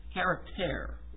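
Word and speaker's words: charakter (χαρακτήρ): In fact, that's exactly how you pronounce the Greek word, charakter.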